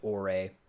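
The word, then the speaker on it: Ouré: We got Ore. I do not like this name for a game. O-U-R-E is how you spell it.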